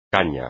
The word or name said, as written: caña